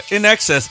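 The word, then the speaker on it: INXS: NXS. I think we talked about that. Yeah, in excess.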